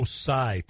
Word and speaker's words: Tsai: This Joe Tsai, T-S-A-I, I think I pronounced it properly. – Yes.